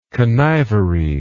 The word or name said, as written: connivery